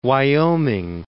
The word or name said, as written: Wyoming